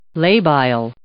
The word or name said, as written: labile